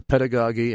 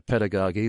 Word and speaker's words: pedagogy: And by the way, I am pronouncing pedagogy the way Spurgeon would, since he was British, and that is the British pronunciation. If he were an American, we would call it pedagogy.